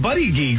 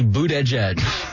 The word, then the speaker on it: Buttigieg: How come nobody's told me it's not Buddy Geek? – Buddy Geek is the way it's spelled, correct. – Okay. – But it is actually Boot Edge Edge, if you can believe that. Buttigieg.